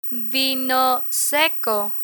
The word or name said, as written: vino seco